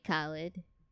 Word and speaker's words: Khaled: DJ Khaled.